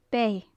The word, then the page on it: beith: gaelic